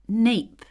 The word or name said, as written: nape